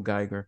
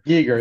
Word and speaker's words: Giger: Again, and I mispronounced his name, H.R. Giger. I think it's actually Giger. I called him Geiger. – Giger is correct.